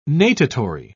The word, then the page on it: natatory: British English